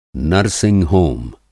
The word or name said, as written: नर्ससिंग-होम